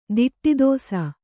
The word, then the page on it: ದಿಟ್ಟಿದೋಸ: kannada